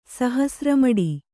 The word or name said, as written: ಸಹಸ್ರಮಡಿ